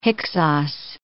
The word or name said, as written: Hyksos